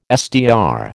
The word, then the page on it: SDR: American English